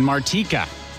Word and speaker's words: Martika: And the number one hit on this date from 1989, I believe it's pronounced Martika.